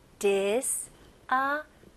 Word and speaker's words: disappointed: 네, 이렇게 긴 단어들은 강세가 보통 한 두 군데 들어가거든요. 여기서는 앞부분, 그래서 disappointed. 그래서 point 여기도 들어갑니다.